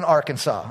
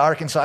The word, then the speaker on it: Arkansas: So listen, tonight we're going to pick on Arkansas, all right? Some laws, you know it's going to be good when we're going out of Arkansas.